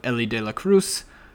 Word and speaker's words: Elly De La Cruz: Uh, I love Elly De La Cruz.